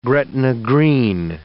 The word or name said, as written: Gretna Green